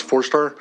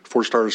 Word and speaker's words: Fourstar: My name is Lats Forstar. Forstar is spelled F-O-U-R-S-T-A-R.